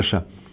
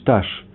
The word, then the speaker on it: Эш: И он посетил тамошнего рэба, раби Мейра Эша. Вот некоторые произносят Таш, а вот в той книге, где я видал, там еще английская транскрипция была, Эш написано.